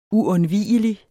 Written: uundvigelig

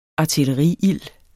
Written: ARTILLERIILD

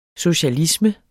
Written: socialisme

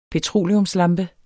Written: PETROLEUMSLAMPER

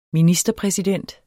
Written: ministerpræsident

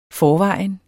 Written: forvejen

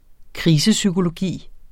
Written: krisepsykologi

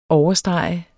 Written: OVERSTREGET

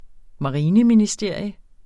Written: marineministerium

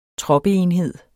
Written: troppeenhed

